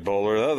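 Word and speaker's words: Boler: Yeah, those, you said it right, Bowler.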